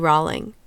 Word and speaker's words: Rolling: In fact, that's why many people refused to pronounce his name the way it's spelled, R-O-L-L-I-N-G. The woman tasked with briefing the media about the investigation every day, Sadie Darnell, had heard that Danny didn't like it when people said his name incorrectly. After all, he wanted to be famous. And so every time she talked about him, Sadie called him Danny Rawling.